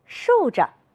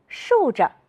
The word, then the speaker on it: 竖着: 扇子，是的，耷拉，竖着。你发现了什么？有的同学说，老师，后一个字都要读成轻声。你们真会观察，我们再来读一读。扇子，是的，耷拉，竖着。